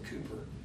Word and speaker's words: Cowper: It's spelled like Cowper, but they tell me that you're supposed to pronounce it Cooper, who wrote, "There is a fountain filled with blood."